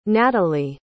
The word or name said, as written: nathalie